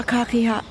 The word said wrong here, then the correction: kakahiaka: Gotta pronounce it correctly. Aloha kakahiaka.